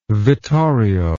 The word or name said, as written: vittorio